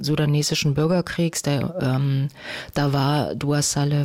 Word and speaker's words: Saleh: Saleh habe ich früher immer gesagt, aber ich habe gelernt, Dua Saleh wird sie ausgesprochen.